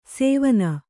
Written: ಸೇವನ

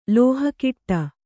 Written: ಲೋಹಕಿಟ್ಟ

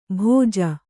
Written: ಭೋಜ

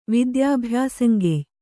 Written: ವಿದ್ಯಾಭ್ಯಾಸಂಗೆಯ್

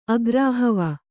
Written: ಅಗ್ರಾಹವ

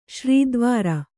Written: ಶ್ರೀದ್ವಾರ

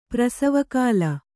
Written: ಪ್ರಸವಕಾಲ

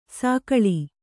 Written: ಸಿಗ್ಗಾಳಿ

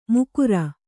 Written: ಮಕರ

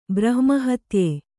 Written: ಬ್ರಹ್ಮಹತ್ಯೆ

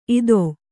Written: ಈತಿ

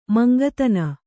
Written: ಮಂಕುದಿಣ್ಣೆ

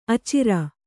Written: ಅಚಿರ

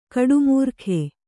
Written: ಗೌಡಮಾರ್ಗ

